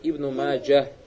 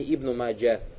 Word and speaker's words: Ибн Маджа: Обязательно говорит Ибн Маджа гибуку произносить, а не Ибну Маджа.